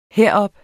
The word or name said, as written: herop